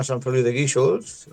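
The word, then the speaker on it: Sant Feliu de Guíxols: Pues sí, yo nací en la población costera de allí en la Costa Brava, que se llama San Feliu de Guíxols. Lo has pronunciado muy bien, aunque es un pueblo que es difícil de pronunciar.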